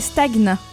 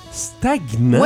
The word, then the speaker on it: stagnant: Eh bien, je t'annonce qu'en français, on doit prononcer stagnant. – Stagnant? – Ouais, c'est pas stagnant, c'est pas comme le GN, non, c'est stagnant.